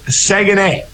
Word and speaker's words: Saguenay: I said it wrong there, which is kind of funny. It's Saguenay. The Chicoutimi Saguenay.